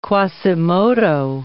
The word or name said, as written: Quasimodo